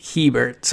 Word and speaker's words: Hebert: So they signed wide receiver Griffin Herbert, which looks a lot like Justin Herbert, but it's not. It's Hebert. So that was a mispronunciation on my part.